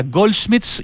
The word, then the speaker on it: Goldsmiths: Goldsmiths University of London.